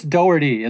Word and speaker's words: Daugherty: It's Chris Daugherty is the one on Survivor, or Daugherty. Daugherty or Daugherty. – Daugherty. And then this is Daughtry.